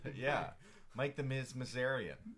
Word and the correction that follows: Mizanin: Mizanin. – Mizanin.